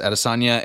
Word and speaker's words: Adesanya: Is it Adesanya? – Yes. – Oh, yes. Is that how you pronounce it? – Yes, Adesanya and...